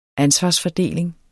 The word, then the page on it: ansvarsfordeling: ˈansvɑs-